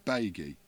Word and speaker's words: baigie: Is B-A-I-G-I-E. Baggy. A plastic bag. Is that Yakka or Crapper? – We'll go for Crapper. – Correct. – Oh, one on the board. – Baggy is a turnip or Swede.